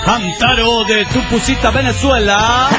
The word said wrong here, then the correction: Tucupita: En realidad es Tucupita, pero no importa.